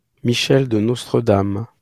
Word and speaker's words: Michel de Nostredame: So, Nostradamus, as we know him, he was born in 1503 in France as, I'm going to try to pronounce this, Michel de Nostredame. – That was pretty spot on.